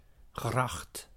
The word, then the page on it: gracht: /ɣrɑxt/